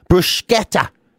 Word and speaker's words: bruschetta: No, apparently you're supposed to put the K sound in. Bruschetta is apparently the proper way to pronounce it.